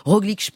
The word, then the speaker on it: Roglič: Et donc Roglič pour la prononciation.